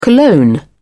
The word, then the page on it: cologne: British English